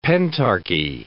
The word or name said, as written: pentarchy